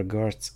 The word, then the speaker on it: Geurtz: And Jasper Geurtz, if I'm pronouncing that correctly, aka Yegers. Hi, Yegers. How are you? – Hey there. Yeah, that's fine.